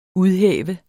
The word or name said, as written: udhæve